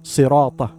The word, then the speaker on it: صراط: صراط, as صاد, should be pronounced with a heavy sound.